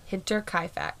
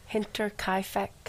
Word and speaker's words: Hinterkaifeck: How do you pronounce it? – Hinterkaifeck. – Hinterkaifeck. That's right, because it's not in this country.